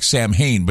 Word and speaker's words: Samhain: For those of you that don't know, Samhain is spelled S-A-M-H-A-I-N, like Samhain, but it's actually pronounced Samhain. So the next time you see that word Samhain, people say, oh, Samhain. No, it's not Samhain, it's Samhain.